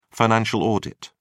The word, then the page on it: financial audit: British English